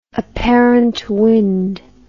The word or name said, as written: apparent wind